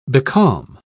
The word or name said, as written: becalm